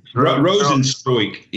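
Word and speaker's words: Rozenstruik: I can't pronounce the dude's name. Can we go back? Rosenstroik. – Rosenstroik, yeah.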